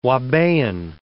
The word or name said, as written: ouabain